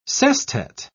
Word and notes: sestet: British English

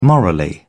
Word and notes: morally: British English

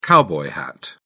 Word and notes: cowboy hat: British English

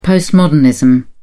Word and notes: postmodernism: British English

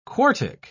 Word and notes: quartic: British English

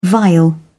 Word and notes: vial: British English

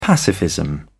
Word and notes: pacifism: British English

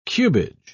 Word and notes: cubage: British English

